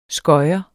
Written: skøjer

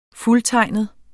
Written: fuldtegnet

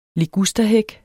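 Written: ligusterhæk

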